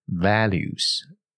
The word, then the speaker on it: values: And makes an effort and makes an effort to spend quality time with them，好，这句话比较重要的字要注意的发音地方是 values，也是一样啊，有点微笑。 va values val 啊，有个 l 出来，你看 v a l u e，对不对？values relationships，好，这个字呢是最近比较红的啊，现在是六月二十一号嘛，哦，在这边要祝各位端午节快乐哈。